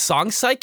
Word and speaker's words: Song Psych: Is Song Psych, can you spell that for the list, please? – Song, like psych, like psychology. S-O-N-G-P-S-Y-C-H. – Is that one word intercapitalized? – Yeah.